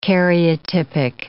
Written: karyotypic

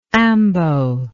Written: ambo

